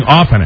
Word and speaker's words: often: Do you pronounce it often or often, Ass? – Often. – You pronounce the T? – I guess I do.